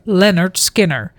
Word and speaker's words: Lynyrd Skynyrd: Oftewel op zijn Engels uitgesproken Lynyrd Skynyrd. En dat hebben ze dus verbasterd naar Lynyrd Skynyrd. En het is waarschijnlijk niet alleen bij ons dat dat een probleem is qua uitspraak. Want hun debuutalbum is letterlijk getiteld Pronounced Lynyrd Skynyrd. Waarbij fonetisch wordt uitgeschreven hoe de band heet.